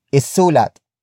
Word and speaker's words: isulat: Word for word it's, isulat mo ang iyong pangalan.